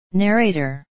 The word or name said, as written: narrator